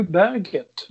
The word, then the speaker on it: Berget: Nej, helt plötsligt var det Berge. – Och då har de alltså frågat, någon har ju frågat honom, och så har han väl sagt att ja, nej, det uttalas Berget. Och så det är ju säkert korrekt att det uttalas då kanske bara utan T.